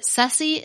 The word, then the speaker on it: surcie: She said that sussy is an alternate pronunciation of Circe, which is a commonly used word in South Carolina to describe a just-because gift or a thoughtful surprise. It's usually spelled S-U-R-C-I-E, and she even had a friend who named her dog Circe because the dog was a delightful but unexpected gift.